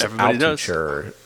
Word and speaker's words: Altucher: The James Altucher show.